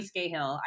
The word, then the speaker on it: Scahill: Another one, I think his name is, I want to say it's Jeremy Scahill. I get the name wrong sometimes. – Yep, that's right.